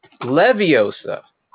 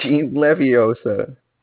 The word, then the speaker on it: Leviosa: You get the accent in the wrong syllable. It's like Leviosa. That's the whole thing. Leviosa.